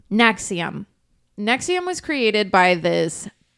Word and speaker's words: NXIVM: It is spelled NXIVM, but it's pronounced...